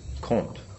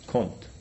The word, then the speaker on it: Comte: Comte. – Do you say the M? – Yes. – Comte.